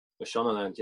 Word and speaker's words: Mashonaland: Mashonaland, yeah.